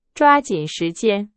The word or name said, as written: 抓紧